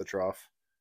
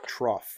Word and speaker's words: trough: Wait, is it trough? – Trough. – Or trough. I actually don't know how it's spelled. – My eyes just rolled out of the back of my head. Trough. T-R-O-U-G-H. – It's a trough. Like what? – No, an F. – G-H. – Okay. – Like fun. G-H-U-N. Fun.